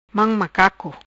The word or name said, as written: Mam macaco